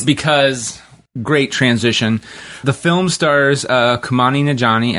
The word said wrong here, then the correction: Kumail Nanjiani: as am I saying that right? – Kumail Nanjiani. Kumail Nanjiani. – Take a drink, everybody. – There you go. – I'm just going to... It's Kumail? – Kumail. – Fuck. Kumail.